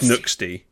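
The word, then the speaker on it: Nxde: Oh, Nude. Yes, thank you. How can I forget? That was on my list. – Come on, say it right. It's Nuxty. It's not Nude, it's Nuxty.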